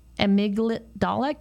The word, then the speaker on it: amygdala: Brain changes of the trauma survivor and an enlarged amygdala. Can you please say that for me? Because I'm not saying it right. – Amygdala. – Thank you.